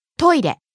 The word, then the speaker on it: トイレ: Next, トイレ。 – Toilet, restroom, bathroom, lavatory. – ト、イ、レ、トイレ。 – Starting high, falling on the second syllable, い、staying low.